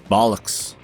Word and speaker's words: Bollocks: Another word I said correctly. Bullocks, right? – Bollocks.